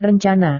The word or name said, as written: rencana